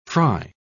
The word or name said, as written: fry